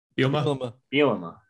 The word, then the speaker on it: Bielema: Bulimia, or how do you say bulimia? Blamia? – Bielema. – There we go.